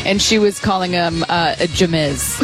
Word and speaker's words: Jameis: And we kept going, it's Jameis. It's just Jameis. And she would go like, okay. And then each time it was a different pronunciation. Everything except for Jameis.